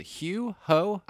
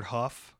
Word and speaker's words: Hough: Juliana, is it Hugh Ho? – I've always heard Huff. – Huff. – Which is a weird pronunciation of that spelling.